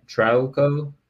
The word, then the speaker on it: Trauco: How do you say his name? Trauco? – Sounds right.